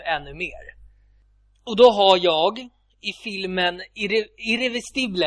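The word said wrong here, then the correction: Irréversible: heter den. Jag är dålig på att uttala det franska. Vet du vilken film jag menar? Med Vincent Cassel och Monica Bellucci. – Ja, Irreversible menar du.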